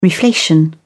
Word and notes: reflation: British English

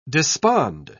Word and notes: despond: British English

